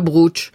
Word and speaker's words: brocciu: brochu, excusez-moi, ma prononciation, dites-moi. – Au brooch. Nous on dit le brooch, mais parce que la fin du mot ne se prononce pas, c'est comme vous dites pas Porto Vecchio, vous dites Porto Vecchio.